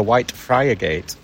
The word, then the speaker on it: Whitefriargate: When you're from Hull, it's Whitefrigate, isn't it? – Whitefrigate, yeah.